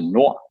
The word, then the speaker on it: Nord: Nord in Danish means, we pronounce it Nord, and that means north, which represents our Nordic heritage and our Nordic design story.